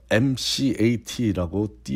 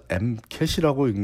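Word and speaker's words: MCAT: 자, 앞에서 MCAT라고 띄어서 읽지 않고 붙여서 MCAT이라고 읽는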